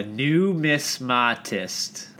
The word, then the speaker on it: numismatist: A numismatist. That's how it's pronounced. Numismatist. – Numismatist.